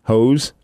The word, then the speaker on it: Heaux: two song on Spotify is a song called These Hoes, and it's spelled H-E-A-U-X, because that's clever. – Spell it again? – H-E-A-U-X, Hoes.